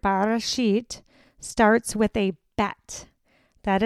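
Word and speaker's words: Bereshit (בראשית): The word for in the beginning is pronounced Bereshit.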